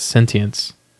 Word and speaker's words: sentience: It's spelled A-L-L-E-G-A-E-O-N. The song is called Proponent for Sinians 3. Check it out. It'll make you laugh. – That was sentience. – Whatever. – You said it weird.